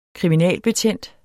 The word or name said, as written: KRIMINALBETJENTS